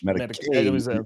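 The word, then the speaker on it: Matakevich: Medikovic, Medikovic, Medikovic. The accent is on the K.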